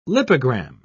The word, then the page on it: lipogram: British English